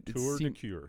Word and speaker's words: Tour de Cure: So, the link that I saw, it was called Tour de Cure. – Yeah. – Okay, so I said that right. – Yep. Tour de Cure.